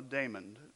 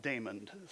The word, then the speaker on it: Damon: I'm always getting my name mispronounced. And they'll go, you mean Raymond, right? And I'm like, no, Damon. Raymond, right? No, Damon.